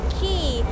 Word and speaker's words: Quay: คีย์สะกดหน้าตาแปลกๆนิดนึงนะ QUAY แต่คำนี้อ่านว่าคีย์เหมือนคีย์กุญแจแล้วค่ะ